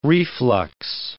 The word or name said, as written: reflux